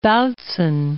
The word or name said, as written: Bautzen